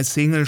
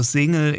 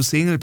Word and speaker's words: Single: Jetzt hat er sich besonders angestrengt, das S bei Single stimmhaft auszusprechen, also Single. In dem Fall ist es aber ein bisschen fehl am Platz, denn es handelt sich ja da um einen Anglizismus und den spricht man eben so aus, wie man es in der Ursprungssprache auch machen würde.